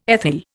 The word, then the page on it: ethyl: Indian